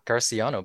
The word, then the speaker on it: Garcellano: Yeah. Yeah, it's actually right. Well, either that or Garcellano, but I'll take both.